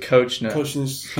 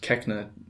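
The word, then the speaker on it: Koechner: David Kochner. I actually think it's supposed to be pronounced Keckner. David Keckner. I'm not sure about it.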